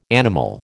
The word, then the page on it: animal: American English